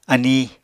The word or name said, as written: aníi